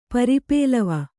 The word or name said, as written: ಪರಿಪ್ಲವ